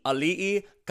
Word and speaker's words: Auli'i: Auli'i.